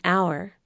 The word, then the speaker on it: hour: But if H is silent, an hour, an honest man.